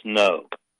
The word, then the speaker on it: Ngo: NGO, and it's pronounced No.